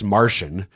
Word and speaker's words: Marcion: It's pronounced Martian, but he was not from Mars. It's Marcion.